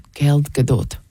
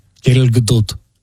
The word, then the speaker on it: Gal Gadot: That's how to say it with an Israeli accent. – Gal Gadot. – Gal Gadot.